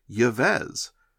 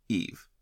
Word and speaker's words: Yves: The research for today's cardinal finally forced me to look into how to pronounce what I once butchered mentally as Yves, which turns out is actually pronounced Eve.